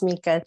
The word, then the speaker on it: Mikka: Yes, it is Mikka.